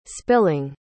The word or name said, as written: spilling